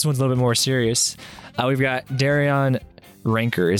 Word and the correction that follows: Wrencher: Did I pronounce that correctly? – No, not at all. – No, Darion Wrencher. – Wrencher.